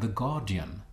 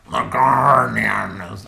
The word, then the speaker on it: The Guardian: Alltså det rimliga är väl att säga The Guardian. Alltså utan hörbart R, eftersom så uttalar man namnet där tidningen ges ut.